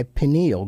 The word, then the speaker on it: pineal: Am I pronouncing that right? Pineal? Pineal gland? – Yeah.